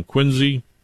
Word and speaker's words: Quincy: Even though the trust is from Quincy. – With a Z. That's how you pronounce it.